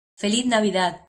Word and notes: ¡Feliz Navidad: Spanish

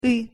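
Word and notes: ы: Russian

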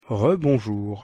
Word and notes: re-bonjour: French

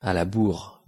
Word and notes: à la bourre: French

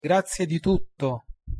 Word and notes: grazie di tutto: Italian

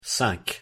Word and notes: cinq: French

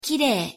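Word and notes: kirei: Japanese

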